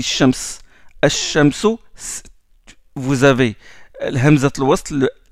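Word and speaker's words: الشمس: Seulement, le lam, vous ne le prononcez pas, vous ne dites pas al-shamsu, vous dites ash-shamsu direct.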